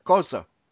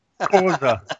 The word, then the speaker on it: Xhosa: Pastor, that is very close to what it was, except that you didn't pronounce that word Xhosa correctly. – Hey, I got it. Xhosa. – Xhosa. – All right. I got to get that click in there. – Xhosa.